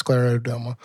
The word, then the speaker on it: scleroderma: I had been saying Sclerodoma, but I think it's Sclerodoma.